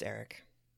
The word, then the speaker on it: Erich: Now, Erich, I think I'm pronouncing his name correctly. – I would, but it's spelled Erich. – Well, it's pronounced like Magneto's given name. It's the Germanic spelling. – Okay. – Yeah. – So is it still pronounced basically like Erich? – It is still pronounced Erich.